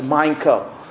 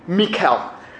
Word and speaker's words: Michal: Some people pronounce her name as Michal, but the Hebrew pronunciation is actually Michal.